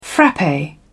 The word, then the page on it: frappe: British English